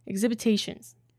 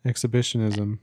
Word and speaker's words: exhibitionism: Exhibitions. – Exhibitionism.